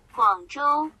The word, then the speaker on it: Guangzhou: I remember I tried pronouncing Gunja Charge. And then second one I realized, you know, maybe I should just use Translate because it can pronounce it better. – Guangzhou. – It does pronounce it better. Thank you, Translate.